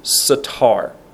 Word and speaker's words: satar: We're going to look at another word that also can be translated as secret or hidden or hide, and it's the word satar. S A T A R, satar.